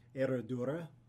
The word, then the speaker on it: Herradura: How do you say this brand? – Herradura. – That's exactly how I said it.